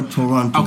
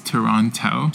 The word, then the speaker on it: Toronto: How do you say Toronto or Toronto? – Okay, so the Hong Kong way is Toronto.